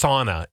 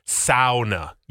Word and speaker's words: sauna: They're like, it's not pronounced sauna. It's sauna. Get it right. It's a Finnish word.